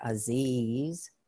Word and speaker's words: Aziz: We're going to be working with Ya-Aziz, A-Z-I-Z, Aziz, like it's spelled, it's pronounced A-Z-E-E-Z, like that, Aziz.